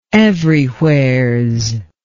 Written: everywheres

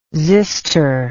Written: xyster

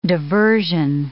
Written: diversion

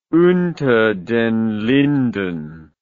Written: unter den linden

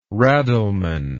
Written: raddleman

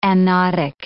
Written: anodic

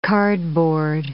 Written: cardboard

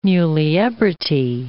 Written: muliebrity

